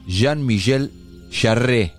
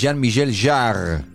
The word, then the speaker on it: Jean-Michel Jarre: Nosotros decíamos Jean-Michel Jarre. Y es Jean-Michel Jarre.